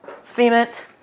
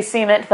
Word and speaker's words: cement: There's no cement, which is not how you say cement. That's just how you say it. If you're from Oklahoma.